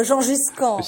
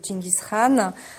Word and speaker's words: Genghis Khan: En France, on parle de Jean Giscan. – C'est vrai. – On parle de Jean Giscan parfois aussi. Mais c'est vrai que la prononciation, sinon mongol et turc, c'est Genghis Khan.